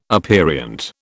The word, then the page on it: aperient: British English